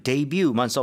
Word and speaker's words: debut: Well, the way Americans say this word is debut. – Debut. – Debut. But maybe the Korean is closer to the French.